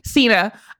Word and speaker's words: Cena: Cena.